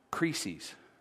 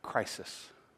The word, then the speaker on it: krisis: The word there that Jesus used in his language was the word krisis. Krisis. Or if you would pronounce it the English way, crisis.